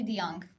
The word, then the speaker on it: De Jong: It's actually De Jong. But okay. De Jong. Thank you. Sorry. – Not De Jong. Not De Jong. – De Jong. – De Jong. – De Jong. – J is silent.